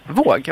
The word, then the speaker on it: Våg: Uttalas det, okej. Okay. Våg. – Ja, aha, okej. – Okay. Det var två A där, glömde jag säga i början. – Och det blir Å på, okej.